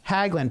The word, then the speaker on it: Haglund: Haglund. – Tobias Haglund. Okay, just like it looks.